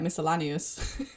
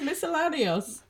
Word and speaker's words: miscellaneous: I used to say it like miscellaneous. It's miscellaneous.